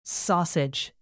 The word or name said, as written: sausage